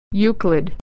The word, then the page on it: Euclid: /y/